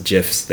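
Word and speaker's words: GIF: An NL Central podcast recounts GIFs they have seen. – Brandon, I know, so. You're fine with GIFs, huh? – Didn't the inventor of the file format say it with the soft G?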